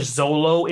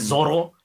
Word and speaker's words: Zoro: in English, Zolo is how they pronounce his name, but it's Zoro in Japanese.